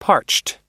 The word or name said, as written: parched